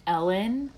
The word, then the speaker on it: Elin: I think her name is pronounced Ellen, but it's spelled Elin. Hildebrand.